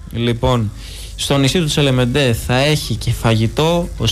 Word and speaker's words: Τσελεμέντε: Με υπέροχη θέα στον πλατή Γυαλό στη Σύφνο, στο νησί του Τσελεμέντε θα έχει φαγητό. – Τσελεμέντε, συγγνώμη. – Τσελεμέντε, γαλλικά θα το τονίζεις.